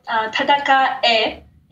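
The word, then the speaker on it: tadakae (戦え): Yeah, so tadakae is what they say, which means like, fight it, like do it. But the original word is tadakau, and tadakau gets conjugated in different ways depending on how you want to say it.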